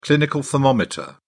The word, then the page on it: clinical thermometer: British English